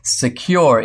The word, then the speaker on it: Sekur: Secure is the better way. It's spelled S-E-K-U-R.